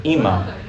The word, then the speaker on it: ima: It's the word for mother, ima.